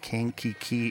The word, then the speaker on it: Kankakee: Joining me today is my special guest, Marissa Rapier from Kankakee, Illinois.